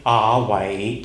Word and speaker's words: 雅偉: But if you happen to be a Cantonese, and if you want to say God's name in Cantonese, you might say Ah-wei.